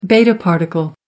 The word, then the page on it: beta particle: American English